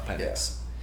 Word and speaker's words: Penix: Michael Penix. Sorry, I want to make sure I said it right. Penix.